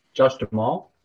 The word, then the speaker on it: Duhamel: You got Joshua Duhamel, or how do you say his name? – Josh Duhamel. – Duhamel. Mr. Fergie. – Duhamel. Josh Duhamel.